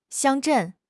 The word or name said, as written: 乡镇